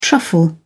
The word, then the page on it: truffle: British English